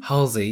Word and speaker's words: Halsey: Is that how you say her name? – Halsey, yes.